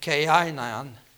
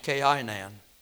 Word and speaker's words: Cainan: It says in verse 12 of chapter 5, and Cainan, that's the way it's pronounced, Cainan, lived 70 years and begat Mahalalel.